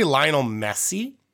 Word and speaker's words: Lionel Messi: I'm going to say Lionel Messi.